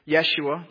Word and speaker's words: Yeshua: In fact, Joshua, which, by the way, is the Hebrew word for Jesus, Jesus's name, if it were pronounced in Hebrew, Yeshua, Joshua, was appointed by Moses to be this person.